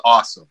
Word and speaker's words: OSYM: You do so all what I like about the project, so the album that was on was called "This Is Awesome," which, you know, you're a wrestling fan, that speaks to your soul, you know.